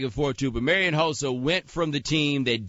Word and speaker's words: Hossa: Hossa. – Marion Hossa. – I pronounced it wrong. – Hossa. Hossa. I didn't play last time.